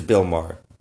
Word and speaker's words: Maher: Yeah, I think it's Bill Maher.